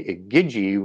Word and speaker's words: Igi: and or part of the Igigi, which is comes from the root Sumerian word Igi, if I've pronounced it right, I think it's a hard G in Sumerian, I-G-I, as it's transliterated, that goes back to watch, as in watchers, as sort of that root word, right?